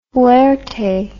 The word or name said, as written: fuerte